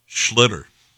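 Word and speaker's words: Schlittler: So, Schlitter. That's how you pronounce his name.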